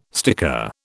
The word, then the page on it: sticker: British English